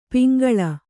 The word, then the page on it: ಪಂಗೋಳಿ: Kannada